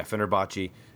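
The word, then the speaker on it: Fenerbahçe: apparently he's in Fenerbahce, or however you pronounce that, in Netherlands. – Fenerbahce. – Old retired. – I think there's a sh in there. – Yeah, Fenerbahce.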